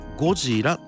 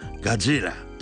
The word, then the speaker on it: ゴジラ: 渡辺謙のこのゴジラの発音ね、ゴジラっていうこの発音が、英語的なガジラとかねじゃなくってちゃんと日本語発音でゴジラというふうに言ってるのも、オリジナルへのリスペクトじゃないですかね。